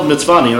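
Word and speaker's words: Mtsvane: No, it's spelled Mitzvani, that's how you pronounce it.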